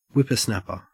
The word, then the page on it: whippersnapper: Australian English